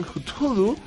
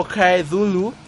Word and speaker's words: Cthulhu: Mucho tiempo después de la muerte de Lovecraft, la pronunciación Cthulhu o Kaedulu se hizo más común y el juego de The Call of Cthulhu la hizo suya.